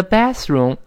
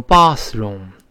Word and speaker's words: bathroom: W A N N A，反正 wanna wanna use 是使用，那 the bathroom 就使用一下卫生间，就上个茅房，上个厕所方便一下。当然，英国人呢把这个卫生间读成 bathroom，他发啊。美国呢这个啊多数变成 a，所以美音中这么读 bathroom bathroom，哎，就是哎，去趟卫生间，就是去趟卫生间。